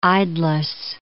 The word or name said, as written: idlesse